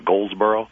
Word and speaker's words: Goldsboro: By the way, I've lived in North Carolina now for just a few years, and I'm trying to remember that there is no D in Goldsboro, as it is pronounced locally. It's one of those phenomena of the accent. Do I have that right? It's Goldsboro? – Yeah, I think you're right, yeah.